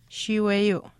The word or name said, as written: shiiweyuu